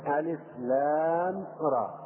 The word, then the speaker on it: الر: هذه حروف تسمى بالحروف المقطعة وتنطق مقطعة، يعني تنطق ألف ألف ولام لام وراء را. ما هي كلمة؟ ما هي كلمة؟ لا، هي حروف تجمع في هذا الشكل وتنطق حروفا: ألف لام ميم، ألف لام راء، ألف لام ميم راء، حاء ميم، طاء هاء، ياء سين.